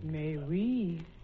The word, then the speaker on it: Mais Oui: May we? – M-A-I-S-O-U-I. – May we? – But yes. It's spelled M-A-I-S-O-U-I, pronounced may we.